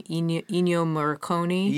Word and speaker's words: Ennio Morricone: Ennio Morricone? – Yeah, yeah.